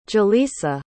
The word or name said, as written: ja'leesa